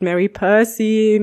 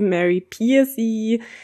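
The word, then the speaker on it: Mary Pearcey: Also es gibt Mary Percy, Mary Piercy, ganz unterschiedliche Weisen.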